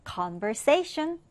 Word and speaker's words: conversation: 자, 여기 발음 기호를 잘 보고 강세를 지켜서 자, 2강세 그리고 1강세 지켜서 읽어볼게요. conversation.